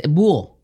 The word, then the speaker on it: buo: That's the word bua, B-U-A for owl? – It's buo. – Bua. Buo. – What is it? – The pronunciation is B-U-O, buo.